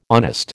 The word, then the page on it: honest: American English